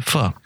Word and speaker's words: pho: Pho. It's spelled pho, but it's pho.